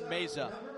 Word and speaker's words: Meza: Here's Adrian Meza. So Meza spelled like Mesa, only with a Z. M-E-Z-A.